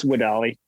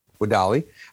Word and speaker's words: WEDALI: Yeah, it's pronounced WEDALI. WEDALI.